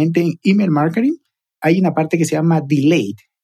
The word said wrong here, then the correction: delight: delight, perdón, siempre lo pronuncio mal, delay es otra cosa.